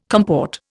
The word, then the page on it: comport: Indian